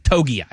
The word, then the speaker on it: Togiai: Kobe Turner, Byron Murphy II, Tavondre Sweat, Moro Ojomo of the Philadelphia Eagles, and Tommy Tongai. Is it Tongai? Is that how he pronounces his last name from the Texans? – Togiai. Yeah.